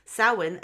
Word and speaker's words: Samhain: In modern times, Samhain, a Gaelic word pronounced Samhain,